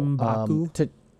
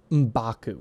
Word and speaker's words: M'Baku: M'Baku. – M'Baku, right.